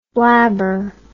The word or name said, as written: blabberer